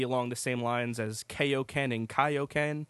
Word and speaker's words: Kaioken: but Kaio should be Kaioken. – Right, yes. That's, and they fixed, well, you know, in the Ocean dubs of the first three movies, they got that pronunciation right. – Well, there were three voice actors of Goku in the U.S., and one of them got it correct. They said Kaioken.